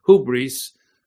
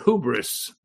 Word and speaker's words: hubris: The English definition, now we've taken that word hubris, B-R-I-S, and the I is pronounced like an E, like it is in Spanish, hubris, and we've made it an English word called hubris, H-U-B-R-I-S, hubris.